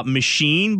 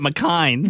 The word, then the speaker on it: machine: And then Gary saw machine, but he pronounced it McKine.